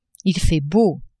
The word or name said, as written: il fait beau